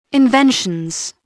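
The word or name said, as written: invention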